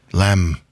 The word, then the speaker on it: lamb: The weird thing about that word is it has a B at the end in English, but it's pronounced the same as the German, lamb.